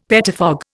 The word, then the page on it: pettifog: Indian